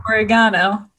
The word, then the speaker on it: oregano: Well, even the, you know, oregano, which I found out I'm pronouncing it wrong, but I'm going to continue to pronounce it wrong. Apparently it's oregano, but it just sounds too fancy for me.